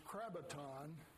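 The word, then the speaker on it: krabaton (κράβαττον): It's interesting that the word is krabaton, which rhymes with sabaton, which means Sabbath.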